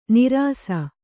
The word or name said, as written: ನೀರಸ